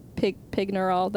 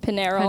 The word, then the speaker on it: Pignerol: He was held there at the same time that the masked prisoner was held in Pignerol. That's not how you say it either. Mm-hmm. Pignerol.